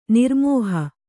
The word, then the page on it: ನಿರ್ಮೋಹೆ: Kannada